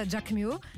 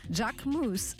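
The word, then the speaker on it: Jacquemus: Вместо «Джакмю» – «Джакмус», а «Живанши» превратился в «Живанши».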